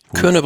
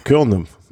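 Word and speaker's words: Kuurne: Das sind, also diese ganzen Rennen finde ich ja sehr, sehr nett und unterhaltsam, aber die Aussprache werde ich... – Körne, Brüssel, Körne. – Körne, okay.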